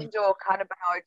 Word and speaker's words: endocannabinoid: Obviously, you've mentioned the personal benefits for you, but I think this will help reduce the stigma, because you mentioned, I'll struggle to pronounce it, that it in a cannabinoid system. – Endocannabinoid system. – That's it.